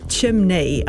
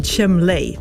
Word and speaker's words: chimney: And anyway, my one was that I've always mispronounced, well, until living memory, chimney. I said chimley. I just thought that's what it was. Thought it was chimley.